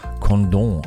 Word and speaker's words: Condom: Aber weil das in Frankreich liegt, wird es natürlich ganz edel Condom ausgesprochen.